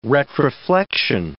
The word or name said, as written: retroflexion